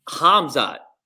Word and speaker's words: Khamzat: So Khamzat, am I saying it right? – Yeah, sure. – Right? I'm not supposed to say the K, right?